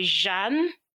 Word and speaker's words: Jeanne: So it's a French derivative name. So it comes from the word like you hear Jean-Pierre, but for female you say Jeanne.